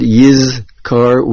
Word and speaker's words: Yizkor: Pasuk Dalid, the word Yizkor, make sure you pronounce clearly the word Yizkor with a Zion.